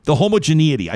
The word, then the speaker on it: homogeneity: Cheryl says, I'm a graduate of the Faculty of Law, and I've seen firsthand the homogeneity. I said that right. Huh? – You did. You did say that right.